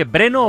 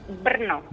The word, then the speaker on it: Brno: Oye, ¿Breno o, o Breno o Bernó? ¿Cómo es finalmente? – Brno. Brno, Con, acento en la R, ¿eh?